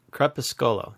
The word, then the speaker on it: Crepuscolo: So it looks like crepusculo to me. – Sculo. There you go. Scolo. Crepuscolo. Crepuscolo. Chris has got it.